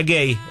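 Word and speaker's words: Guéhi: Consa, y se escribe Gehi, pero se pronuncia Gay, el central, junto con Nico Reilly o Spence.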